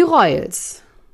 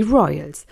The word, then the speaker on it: Royals: Die Royals. Nee, da war jetzt das ganz falsch ausgesprochen. Die Royals.